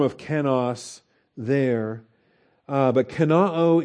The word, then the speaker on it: kenoo: The verb is kenoo. K E N O O it's got an omicron omega ending, so the short o followed by the long o.